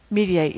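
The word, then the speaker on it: Mediaite: This guy, Trump, this is Mediate. How's that how you pronounce it? – Mediate. Yeah, they're very, I think so.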